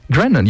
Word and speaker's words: Grennan: En samen met Tom Grennan, zeg ik dat goed? Grennan, ja, Tom Grennan.